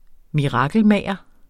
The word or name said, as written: mirakelmager